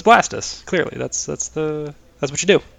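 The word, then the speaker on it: Blastoise: It's actually Blastus. It's pronounced okay. Blastus. Yeah, because it's like, like a blast of water and a tortoise.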